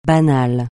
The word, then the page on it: banal: francais